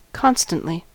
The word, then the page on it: constantly: American English